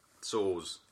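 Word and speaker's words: Soares: I mean, it doesn't look like Suarez. – No, that is how you say it. – Soars.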